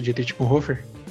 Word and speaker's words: Dietrich Bonhoeffer: Falei certo, Dietrich Bonhoeffer? Falou.